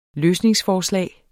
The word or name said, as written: løsningsforslag